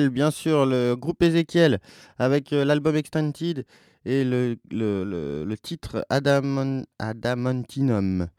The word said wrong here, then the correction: Adamantium: Et voilà, donc c'est ça, je le prononce bien. Adamantium. Voilà, Adamantium.